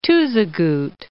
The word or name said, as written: Tuzigoot National Monument